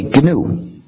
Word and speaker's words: GNU: Nous avons travaillé dessus pendant presque 24 ans. Il n'est plus nouveau, mais il reste GNU. Donc il faut le prononcer GNU. Pas nous et pas Linux.